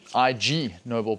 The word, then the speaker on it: Ig Nobel: It's actually pronounced Ig Nobel. And as you can guess, it's a play on words of the actual Nobel Prizes.